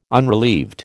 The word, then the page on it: unrelieved: American English